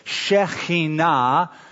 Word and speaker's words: Shekinah: They called this the Shekinah, that's how most of us pronounce it. Shekinah is the correct pronunciation.